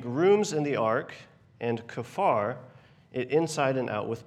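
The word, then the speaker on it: Kafar (כפר): Kafar. Actually, that's exactly it. It's kind of like Jafar from Aladdin, but with a K.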